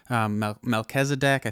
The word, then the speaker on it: Melchizedek: And the connection between Melchizedek, I think I'm pronouncing it right.